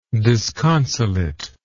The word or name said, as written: disconsolateness